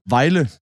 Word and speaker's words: Vejle: Vejle.